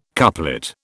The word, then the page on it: couplet: British English